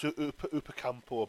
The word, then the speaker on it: Upamecano: It's Upamecano, I believe it's pronounced from from um, Leipzig.